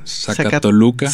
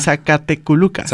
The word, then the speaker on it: Zacatecoluca: Es como si funcionara el nombre de Zacatecas y Toluca. – Zacatoluca. – Zacatecoluca.